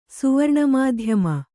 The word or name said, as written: ಸುವರ್ಣಮಾಧ್ಯಮ